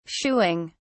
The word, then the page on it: shooing: British English